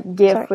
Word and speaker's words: GIF: I also want to point out that they say GIF with a hard G.